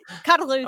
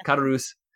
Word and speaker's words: Caderousse: And Mondego is in love with Mercedes, and Caderousse, I can't remember if that's how that's pronounced from the audiobook. – Yeah, Caderousse. – Okay, yeah. Caderousse.